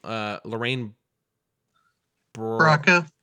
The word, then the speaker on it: Lorraine Bracco: And did I say that right? For some reason, it still doesn't sound right. It doesn't matter. Anyways. – Yeah, Lorraine Bracco. Yep.